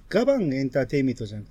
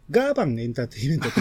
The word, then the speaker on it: ガーバンエンターテイメント: あれでも、発音は、ガバンエンターテイメントじゃなくて、うん、ガーバンエンターテイメントって言ってほしい。細かいこと言うとね。